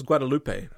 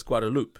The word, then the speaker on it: Guadeloupe: I always thought it was Guadeloupe, but it's Guadeloupe, is the way she was saying it.